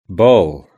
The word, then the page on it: ball: British English